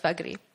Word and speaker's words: Vagdi: That's spelled V-A-G-D-I. It's pronounced Vagri.